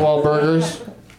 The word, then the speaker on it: Wahlburgers: Are you saying you just go into Wahlberg's and drink a shake? – Oh, shit. I should start doing that. I mean Wahlburgers. – It's really... Have you been there yet? – Sorry, I mispronounced it.